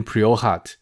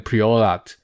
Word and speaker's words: Priorat: 诶，我忽然想到一件事，我前面是不是发音 Priorat 啊？我刚才一直念错，应该要念 Priorat，因为前面都在讲法国酒，不小心用法文念了西班牙的地名 P R I R O T Priorat。